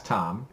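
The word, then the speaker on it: Thom: I did just look up in the glossary for The Eye of the World, and it does give the pronunciation for Tom as Tom, even though it's T-H-O-M, but it gives the pronunciation for Rand Al Thor as Thor, T-H-O-R.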